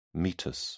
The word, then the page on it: meatus: British English